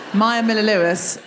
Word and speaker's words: Maia Miller-Lewis: Maya Miller-Lewis, Maya Miller-Lewis, said it correctly the first time, Maya Miller-Lewis, M-A-I-A, go and see it.